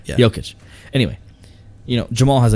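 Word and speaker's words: Jokić: I keep saying Jokic, and it's not how you say it, but I really don't care.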